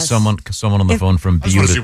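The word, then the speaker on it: Buda: And we were learning how to pronounce Beulah.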